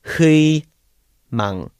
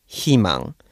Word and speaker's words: 희망: The Korean for, word for hope is hui mang, but you would pronounce it as 희망 because you're adding an H at the, the beginning of the 의 sound. When you add another consonant, the 의 turns to E.